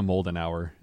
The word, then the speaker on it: Moldenhauer: Man, you pronounced it a lot better than I did last time. I was like, Marija... Modern her okay.